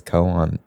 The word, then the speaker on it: Koan: Well, I say Koan largely for like the Zen riddle. I figured that's what it was, but I think you could also pronounce it Cone.